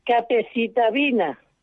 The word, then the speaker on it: capecitabina: me estoy tomando la capecetabina, si lo digo bien. Capecitabina.